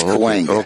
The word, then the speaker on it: Cahuenga: Coalinga? – Cahuenga. We both butchered it. – No, but how's it spelled? – C-A-H-U-N-G-A. I think it's Cahuenga.